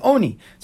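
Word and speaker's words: oni (עני): So the Gemara says, Rabbi Yossi Haglili, he responds, Miksiv ani, mi karinan ani, but the way we pronounce it is not ani, the way we pronounce it is oni.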